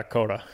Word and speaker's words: Coatta: Sam Coda, is it? – Nah. Yeah, you got that right. It's okay either way. I don't mind if it's mispronounced, but yeah, Coda.